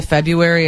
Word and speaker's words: February: I'm born in that month, but I just say February. It's like the R is silent.